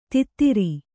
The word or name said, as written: ಧೋತ್ರ